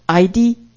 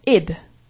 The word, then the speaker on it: Id: And so this is called ID, ego, and superego. And but... Huh? I don't know. It's ID? Id. Okay, Latin here. It's not ID. It's pronounced id.